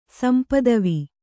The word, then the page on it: ಸಂಪದವಿ: Kannada